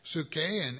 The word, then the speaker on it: psuche: and it's spelled P-S-U-C-H-E. P-S-U-C-H-E, it is psuche.